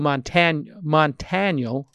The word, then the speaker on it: Montaniel: Seven is spelled out S-E-V-E-N, obviously. And his name is Joe Montaniel, like Daniel, but with a T.